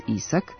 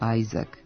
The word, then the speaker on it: Isaac: Naprimjer, američkog pevača i glumca Chrisa Isaaca nećemo zvati Chris Isaac, ali jedan od najvećih pisaca naučno-fantastičnih romana nije Isaac, nego Isaac Asimov.